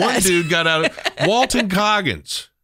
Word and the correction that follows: Walton Goggins: Walton Goggins.